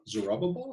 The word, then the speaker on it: Zerubbabel: And then this other Zerubbabel, is that how you say it? – That's right. It's a mouthful, but that is his name.